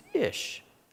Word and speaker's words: ish: The pronunciation for that Hebrew word that's used for man is ish. We kind of spell it I-S-H. But fire is E-S-H, esh.